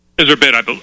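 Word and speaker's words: Iserbyt: And how does she pronounce her name? Iserbit or Iserbite? – Iserbit, I believe. I think it's Iserbit, yes.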